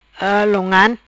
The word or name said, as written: Long Ngan